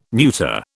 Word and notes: neuter: British English

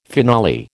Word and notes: finale: American English

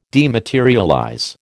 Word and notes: dematerialize: American English

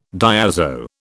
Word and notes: diazo: British English

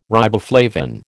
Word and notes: riboflavin: American English

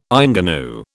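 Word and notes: ingénue: British English

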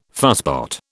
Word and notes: fusspot: British English

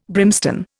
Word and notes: brimstone: Indian

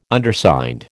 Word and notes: Undersigned: American English

